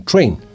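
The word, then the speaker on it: Trane: Sin embargo, Train, T-R-A-N-E, a pesar de que se escribe así, tiene el mismo sonido que la palabra que viene de tren en inglés train, T-R-A-I-N.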